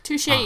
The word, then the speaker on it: Touché: So it's called Touché, and most of her poems have French titles, which I loved, but made me super nervous because I was going to mispronounce all of them. – Touché, you got, though.